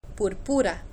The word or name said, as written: purpura